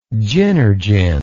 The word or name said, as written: gynergen